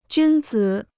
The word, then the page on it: 君子: jūn zǐ